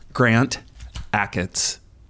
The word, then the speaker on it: Grant Achatz: Grant Achatz. Did I say it right? – Yes.